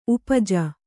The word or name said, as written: ಉಪಜ